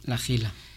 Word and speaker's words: La Jila: Se escribe con H, como se escribe Oz y se dice el Collao Hoz, o se escribe Ozalba y se dice allí Collao Ozalba. Y como dicen por allí, el que no diga Hacha, Higo y Higuera no es de esta tierra, ¿no?